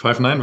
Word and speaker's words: Five9: Ja, ist richtig. Five9 wird es ausgesprochen.